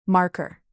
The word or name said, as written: marker